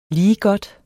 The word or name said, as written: ligegodt